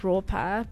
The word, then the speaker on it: Ropa: Well, on my Instagram handle is Ropa, but it's spelled RAW, so raw underscore PAH. Ropa.